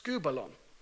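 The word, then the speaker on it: skubalon (σκύβαλον): Now, in the Greek, it's pronounced skubalon, okay? Skubalon.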